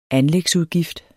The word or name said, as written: anlægsudgift